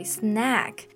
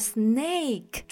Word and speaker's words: snack: Exactly, snack。那中间的这个发音其实是梅花音 a snack，就是我们的零食。如果你发成了 snake，中间用的这个 a 的音呢，它就会变成蛇的意思。